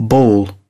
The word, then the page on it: ball: British English